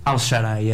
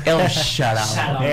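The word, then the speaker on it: El Shaarawy: Stefan Al-Sharai, é assim que se diz, acho eu. – É assim que se pronuncia. – Al-Sharai.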